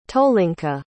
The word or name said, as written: tolinka